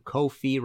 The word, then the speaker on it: Ko-fi: If you like this episode and want to support future episodes, you can go to Ko-fi, rhymes with no fee, .com/MartinPopoff and hit the red support button.